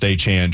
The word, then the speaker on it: Etienne: And now it's Trevor Etienne, just for those that are a little confused, Travis Etienne said his name is actually pronounced Achan.